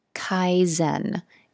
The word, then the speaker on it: Kaizen: Kaizen. It's spelled K-A-I-Z-E-N. And it, I believe it's a Japanese word, and it's about applying continuous daily small improvements.